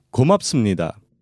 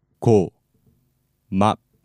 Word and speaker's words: 고맙습니다: Next is 고맙습니다. One more time slowly. 고맙습니다. Let's break it down by syllable. 고맙습니다.